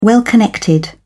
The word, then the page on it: well-connected: British English